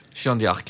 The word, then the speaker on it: Jeanne d'Arc: Fransk, Jeanne d'Arc. – Jeanne d'Arc, ja.